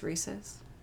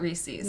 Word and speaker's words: Reese's: And by the way, it's pronounced Reese's. – Not Reese's.